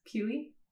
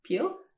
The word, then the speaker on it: Peele: I guess like the way it's spelt, I can see why if you haven't heard his name said out loud, you would say Peely. – Peely, yeah. – Yeah, but it's just Peel.